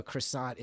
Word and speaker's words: croissant: You're claiming that your love of a croissant is not highbrow or an effort to be fancy, and yet you pronounce it croissant, which I will admit is the correct pronunciation.